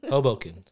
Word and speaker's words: Hoboken: How do you say it? – Hoboken. – Hoboken. It's just like a one word.